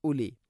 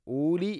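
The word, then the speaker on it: أولي: ഫഇതബിറു യാ ഉലിൽ അബ്സാർ, യാ ഉലി മദ് മുൻഫസിൽ, നാലോ അഞ്ചോ ഹർക്കത്തിൻ്റെ ഖദ്ർ നീട്ടാവുന്ന മദ്. അതുപോലെ തന്നെ ഉലി എന്നാണ്, ഊലി എന്ന് ഒരിക്കലും പറയരുത്. വാവിൻ്റെ മേലേക്കാണ് സുക്കൂൻ, അത് ഉച്ചരിക്കുകയില്ല എന്നുള്ളതിനെ സൂചിപ്പിക്കുന്നതാണ്.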